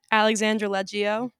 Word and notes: Alexandra Leggio: female

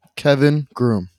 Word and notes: Kevin Groom: male

